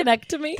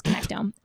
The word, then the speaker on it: connectome: That's not how you say it. So it's connectome.